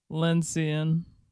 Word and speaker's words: lyncean: The next word is Lynsian. – Lynsian, spell it. – L-Y-N-C-E-A-N. – Lynsian.